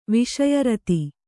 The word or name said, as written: ವಿಷಯರತಿ